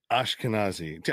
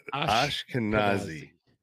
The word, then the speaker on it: Ashkenazi: There are the Anakasazi, I think, or I don't know. – Ashkenazi. – I can never pronounce that. Ashkenazi.